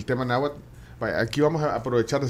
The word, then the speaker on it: náhuatl: Fíjate que, bueno, ahí hay que aclarar de que es náhuatl con TL, Porque esas son las variantes mexicanas. Ah. Es el, el náhuatl. Se pronuncia así, al final, que la TL.